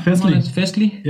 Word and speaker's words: Festlig: Yeah, so this is Festlig. Am I saying that right? – Yeah, Festlig.